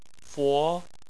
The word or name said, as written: fo2